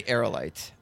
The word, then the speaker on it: Aerolite: It's Aerolite. It's definitely Aerolite.